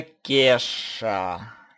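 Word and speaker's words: Ke$ha: Это Кеша. Пишется, кстати, английскими КЕ, потом знак доллара и HA.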